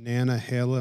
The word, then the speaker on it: Nantahala: but they're right by the Smoky Mountains and Nantahala.